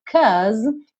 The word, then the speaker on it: cause: Olha só, esse cause, que é aí uma alternativa ao because, termina com uma letra E. Esse E não é pronunciado, tá pintadinho aqui de cinza.